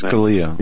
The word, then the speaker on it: Scalia: You know, I'm not going to comment on how or why or if the Supreme Court Justice Scalia, how do you pronounce his name? I can't ever pronounce his Scalia. – Name. – Yeah, Antonin Scalia. Scalia. I was schooled on his pronunciation.